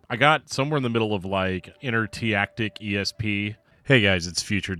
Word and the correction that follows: Inertiatic: The word is pronounced inertiatic, not whatever I just said.